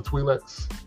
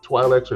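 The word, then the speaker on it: Twi'lek: You know about the Twi'leks? Usually people you see dancing with the little tails on their heads. – Yes. – Those are the Twi'leks or Twi'leks, however you want to pronounce it, from the planet Ryloth.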